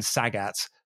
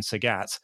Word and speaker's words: Sagat: And I thought to myself, is this one of those things where I've been, you know, when I was a kid, I thought it was Ryu and Sagat, where I've then, you know, since learned that it's Ryu and Sagat.